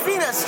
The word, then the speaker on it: Venus: I'm Venus, just like the planet. – Just U.S.? V-E-N-U-S? – V-N-U-S.